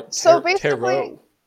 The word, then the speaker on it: tarot: Or tarot. – Yes, it's T-A-R-O-T. That's how it's tarot. Spelled, but it's pronounced tarot. – Tarot.